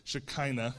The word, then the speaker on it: Shekinah: Shekinah is how you say that word.